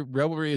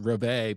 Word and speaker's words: reveille: But it turned out it's actually revelry is actually pronounced reveille, but I don't think anybody in America pronounces it that way.